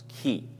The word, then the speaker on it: chi: Chiastic structure comes from the Greek letter chi. It looks like an X, but this is actually how it is pronounced, chi.